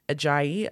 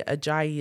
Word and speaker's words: Ajayi: So yeah, I'm on LinkedIn, Deborah Ajayi. Ajayi is A-J-A-Y-I.